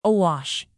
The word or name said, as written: awash